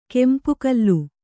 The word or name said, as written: ಕೆಂಪುಕಲ್ಲು